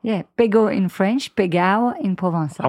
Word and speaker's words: Pegau: Yes, you have to pronounce Pegao. – Pegao. – If so you want to speak in thing, Provençal.